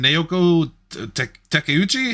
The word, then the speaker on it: Naoko Takeuchi: I know Hayao Miyazaki and Naoko Takeuchi.